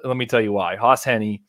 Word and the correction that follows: Hejny: I hope I'm saying that right. It has a spelling pronunciation Hejny. on it. Hejny.